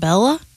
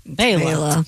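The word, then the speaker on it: Béla: Born in 1958, the great banjo player, Mr. Fleck, first name B-E-L-A. – B-E-L-A. It's not Bella? – It's Bela. – Bela.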